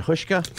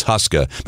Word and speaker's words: Huska: The whole story behind that is Huska is the way it is pronounced in Slovakia. When he came to the United States, he wanted it pronounced and he wanted it Americanized, so it's Huska.